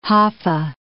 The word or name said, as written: Hoffa